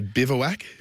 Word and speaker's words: Bivouac: And so looks like the Cummings has got this all wrapped up with Boovervac. – Sorry? – Who was that? – Boovervac. – Right, okay. – How do you pronounce it? – I'd say Bivouac. – Bivouac. – Yeah, I'd go the Bivouac.